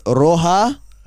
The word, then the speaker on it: roja: So it's Roja. – Roja. – R-O-J-A, but H, J is spelled like, says Roja. – Like H.